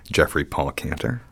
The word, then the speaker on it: Geoffrey Paul Cantor: Geoffrey Paul Cantor.